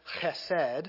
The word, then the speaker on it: chesed: It's chesed. It uses one of those great Hebrew guttural sounds. If you're not saying it right, you should be hawking a loogie as you say it, right?